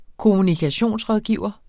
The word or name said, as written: kommunikationsrådgiver